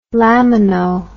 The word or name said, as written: lamino